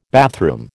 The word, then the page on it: bathroom: American English